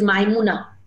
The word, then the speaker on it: Maimuna: Her name is Maimuna. Maimuna. – Yeah, Maimuna. Like to rhyme with Namuna.